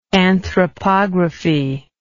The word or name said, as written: anthropography